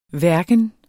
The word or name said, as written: hverken